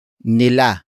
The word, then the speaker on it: nila: The next word is nila. Nila. Nila. Nila is spelled as N-I-L-A.